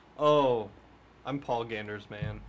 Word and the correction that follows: Gandersman: Not pronounced like that. I don't know why I said it. Paul Gandersman.